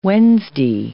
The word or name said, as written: Wednesday